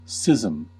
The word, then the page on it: schism: American English